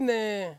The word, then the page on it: ne: Ansus